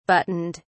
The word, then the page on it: buttoned: British English